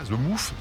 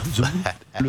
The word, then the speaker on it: The Moth: Ça se prononce comme ça, The Moth. The Moth. – Non, c'est bien, The Moth. – Oh, the Moth. The Moth. – Le TH est parfaitement prononcé, bravo.